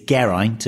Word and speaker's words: Geraint: It's G-E-R-A-I-N-T. That's what I have to say. It's Geraint and it rhymes with pint.